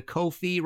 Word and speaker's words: Ko-fi: If you did like this show and want to support future episodes, please go to Ko-fi, rhymes with no fee, .com/MartinPopoff.